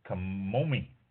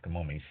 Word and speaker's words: Camomi: Camomi. I think that's how it's pronounced. Camomi. C-A-M-O-M-I, Camomi Wines.